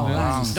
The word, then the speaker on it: Lens: Se pronuncia Lens. ¿Dónde queda el Santetien, don Javier? – Me gusta, ¿no? – El promedio de echada del Lance es el mismo de Millonarios.